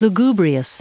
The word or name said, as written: lugubrious